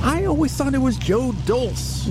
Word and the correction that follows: Dolce: No, it's Dolce.